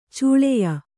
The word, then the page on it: ಛಳೆಯ: Kannada